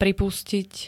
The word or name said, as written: pripustit